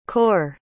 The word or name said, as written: kaur